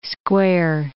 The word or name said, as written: square